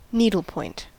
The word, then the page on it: needlepoint: American English